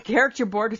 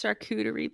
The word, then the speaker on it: charcuterie: It's not a character board. – It's a character board without the character. – It's charcuterie, please. Say it right. Can you right. please just say that right? – I'm not saying it right.